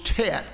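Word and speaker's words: Tet (ט): We'd say T-E-T, or we'd call it Tet.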